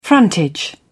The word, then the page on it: frontage: British English